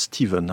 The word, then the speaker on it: Stephen: Ça s'écrit Stephen, on prononce Stephen hein, en anglais.